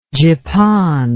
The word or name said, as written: gipon